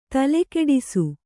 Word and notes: ತಲೆಕೆಡಿಸು: tale keḍisu

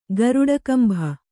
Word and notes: ಗರುಡಗಂಭ: garuḍagambha

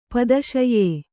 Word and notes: ಪಿತ್ತಾಶಯ: pittāśaya

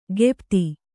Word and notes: ಗೆಪ್ತಿ: gepti

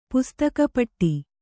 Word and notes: ಪುಸ್ತಕಪಟ್ಟಿ: kannada

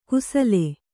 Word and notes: ಕುಸಲೆ: kusale

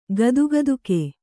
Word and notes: ಗದ್ಗದುಕೆ: gadgaduke

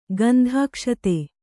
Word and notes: ಗಂಧಾಕ್ಷತೆ: gandhākṣate

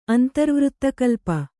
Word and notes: ಅಂತರ್ವೃತ್ತಕಲ್ಪ: kannada

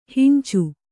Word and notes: ಹಂಚಿ: hanci

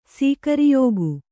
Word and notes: ಸೀಗುರಿಯಿಕ್ಕು: sīguriyikku

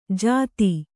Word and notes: ಜತಿ: jati

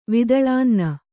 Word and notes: ವಿದಳಾನ್ನ: Kannada

